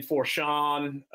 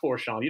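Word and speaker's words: Foshan: The fan interaction in Fushan, I gotta say it right, not Forshawn. You don't pronounce that R.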